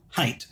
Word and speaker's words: Haidt: Haidt. – Okay. – Like you would pronounce it like height, like how tall is something? What is its height? – Yes. – But it is spelled H-A-I-D-T.